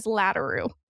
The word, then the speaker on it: Laderoute: My maiden name is Laderoute.